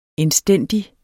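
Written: INDSTÆNDIGT

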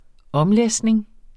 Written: omlæsning

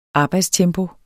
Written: arbejdstempo

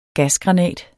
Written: gasgranat

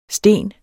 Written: stene

